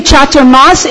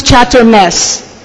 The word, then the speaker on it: chaturmas: Prabhupada told one of my godbrothers, whenever you do chaturmas, it becomes chaturmes.